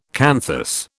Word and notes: canthus: British English

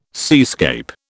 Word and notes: seascape: British English

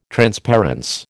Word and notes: transparence: American English

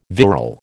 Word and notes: virile: American English

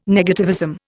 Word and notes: negativism: Indian

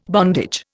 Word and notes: bondage: Indian

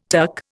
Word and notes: TUC: Indian

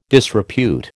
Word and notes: disrepute: American English

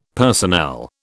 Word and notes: personnel: British English

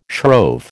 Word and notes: shrove: American English

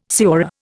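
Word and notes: señor: Indian

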